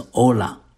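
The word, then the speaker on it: olah (עֹלָה): 利未记第一章是讲到燔祭，燔祭的希伯来文发音是 olah，这个字的意思是 ascent，就是上升的意思。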